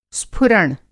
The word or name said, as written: स्फुरण